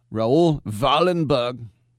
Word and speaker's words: Wallenberg: You pronounce the W like a V. Aha. Raul Wallenberg.